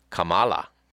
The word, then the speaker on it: Kamala: She is, in fact, half of Indian extraction, and that if it were pronounced the Indian way, it would be Kamala.